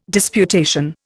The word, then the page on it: disputation: Indian